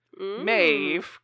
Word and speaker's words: Medb: She is Queen Maeve, and it's spelled a variety of different ways. So M-A-E-V-E or M-E-D-B is another way that it's spelled, but still pronounced Maeve.